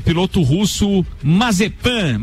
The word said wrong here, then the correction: Mazepin: Mazepin, na verdade Isso.